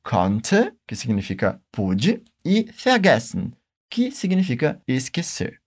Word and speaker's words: vergessen: Se você prestar atenção, esse último verbo tem a sílaba fea no começo. No alemão, nós temos partículas separáveis e não separáveis. No caso, essa é uma partícula não separável. Por isso, a gente nunca vai colocar a tônica da palavra nela, sempre na sílaba que vem logo em seguida. Por isso nós falamos vergessen.